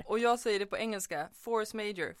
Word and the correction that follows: force majeure: force majeure.